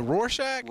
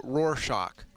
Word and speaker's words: Rorschach: Rorschach. – Like, is it Rorschach? – Rorschach.